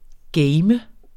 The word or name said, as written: game